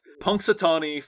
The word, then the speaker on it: Punxsutawney: Uh, not only does it say um, the groundhog, it says groundhog gobbler's knob, and finally on the side it says Punxsutawney Phil you might not hear it phonetically, but there is an X in the middle of the word Punxsutawney.